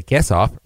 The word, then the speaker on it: Quesoff: Well, the Quesoff, rhymes with face off, happens on August 25th.